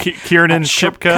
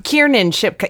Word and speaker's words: Kiernan Shipka: Kiernan Shipka? – Kiernan Shipka.